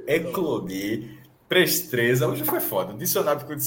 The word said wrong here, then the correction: presteza: Presteza. Prest, é prest, Presteza. presteza. – Então, eu falei certo, presteza, tinha falado certo. Não, não, não tem o segundo R.